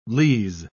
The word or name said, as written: lees